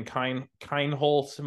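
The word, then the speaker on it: Kienholz: Washington was very early in on this Lincoln Kindholz, am I pronouncing that right? The, yep.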